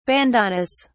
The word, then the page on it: bandanas: British English